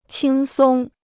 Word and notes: 轻松: qīng sōng

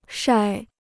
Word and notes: 晒: shài

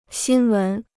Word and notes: 新闻: xīn wén

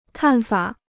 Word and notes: 看法: kàn fǎ

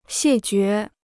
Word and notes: 谢绝: xiè jué

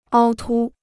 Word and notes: 凹凸: āo tū

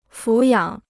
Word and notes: 抚养: fǔ yǎng

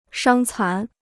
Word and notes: 伤残: shāng cán

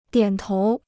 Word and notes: 点头: diǎn tóu

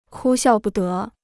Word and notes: 哭笑不得: kū xiào bù dé

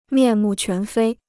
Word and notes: 面目全非: miàn mù quán fēi

